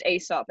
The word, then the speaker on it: Aesop: No, It is pronounced Aesop, everyone on the chat.